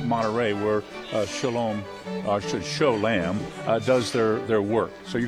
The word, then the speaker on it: Cholame: It looks like Sholami, but it isn't. It's pronounced Sholam. It's spelled C-H-O-L-A-M-E.